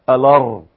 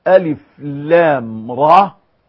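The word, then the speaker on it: الر: والله الذي لا إله إلا هو، لو جئت إلى إنسان يمسك بالمصحف لأول مرة وقلت له اقرأ هذه السورة التي نحن بصددها، إذا قرأ يقول لك ألر ألر، لكي ينطقها ألف لام را لابد من معلم يعلمه هذا، ولا بد من مفصل يفصل له هذا.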